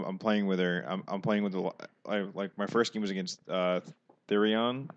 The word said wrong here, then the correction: Thyron: Is that how you say it? – Thyron. – Okay. Tyrone. – Thyron.